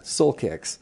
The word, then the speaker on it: Soulkix: Tell me about Soul Kicks. – Okay. – First, let me, when I say it, I got to spell it. One word, Soulkix. – That's right. – Soul Kicks.